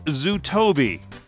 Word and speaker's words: Zutobi: Leo is the co-founder of an app called Zootobi. It's spelled Z-U-T-O-B-I.